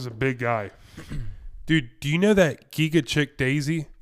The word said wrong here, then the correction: Chikadze: Giga Chikadze? – Thank you.